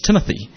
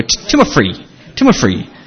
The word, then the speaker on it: Timothy: It's Timothy. And in Japan, my name is Temote, or actually Fifi. And the reason why it's Fifi is Japanese can't pronounce Timothy. They go, Timofree.